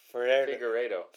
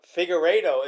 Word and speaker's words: Figueiredo: But um, Formiga was just able to stay outside, and then as soon as how do you say his name? – Figueiredo. – Figueiredo.